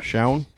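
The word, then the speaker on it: Shoun: How are we pronouncing this dude's name? Shown? – Shown? – Shown? – Shan? – No. It's S-H-O-U-N. – Shown.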